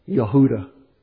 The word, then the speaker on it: Yehuda: All those names that from the Bible that we pronounce with our English J sound actually begin with a Y sound. Yehuda was Judah's name. And from what I've read, our English J was once pronounced like a Y.